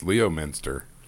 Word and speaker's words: Leominster: Even though my town that my guy is from, Leominster, is actually pronounced Leominster.